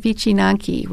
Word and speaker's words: wycinanki: I wanted to focus a little bit on a Polish folk art, and I chose something called wycinanki, which is paper cutting. And I'm glad you pronounced that for me. – yes, that's as I understand, that's what my Polish friends tell me.